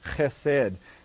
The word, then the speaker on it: chesed: It's the Hebrew word, I'll pronounce it, chesed. Some lexicons and Greek dictionaries would put a C in front of the H, H-E-S-E-D. Some would put a C to give that hard H sound, this chesed.